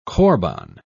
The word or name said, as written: corban